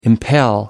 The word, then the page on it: impel: American English